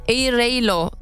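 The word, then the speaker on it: Airalo: Elinizin altında Air Alo isimli, Air Alo diye de telaffuz edebilirsiniz. A harfiyle başlıyor.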